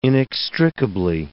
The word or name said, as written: inextricably